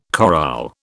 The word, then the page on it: chorale: British English